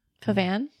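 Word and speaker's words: pavane: Am I pronouncing it right? Pevan? – Pavan? – Pavan.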